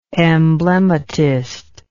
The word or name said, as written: emblematist